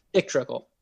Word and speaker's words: Dick Trickle: Dick... Trickle. – Say it in one sentence, please. – Dick Trickle. – Why would you call Correct. His name is Dick Trickle.